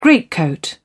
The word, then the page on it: greatcoat: British English